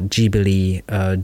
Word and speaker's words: Ghibli: But in Japanese, it's Ghibli, Ghibli Studio.